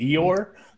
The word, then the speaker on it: Eeyore: But what is that Igor from Winnie the Pooh? That's what I thought. That's what I heard. – What? – Oh, you mean Eeyore? – Yeah.